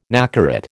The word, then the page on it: nacarat: American English